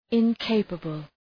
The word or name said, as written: incapable